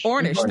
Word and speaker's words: Ornish: My question for you, the Amish, I hope I'm pronouncing that right, Ornish, thank you, reversal program at Beacom Health that Dave participated in is a program featured in the documentary Optimizing Life.